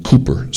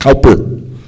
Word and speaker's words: Cowper: William Cooper, C-O-W-I-E-R, often mispronounced Cowper,